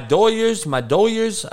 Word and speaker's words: Doyers: My Doyers, that's the L.A. Dodgers for all you the non-Hispanic speaking folks out here in L.A. Speak the Doyers. That's how we pronounce the Dodgers.